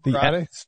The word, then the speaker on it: Emirates: The Emirates.